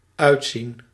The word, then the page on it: sembler: France; /sɑ̃.ble/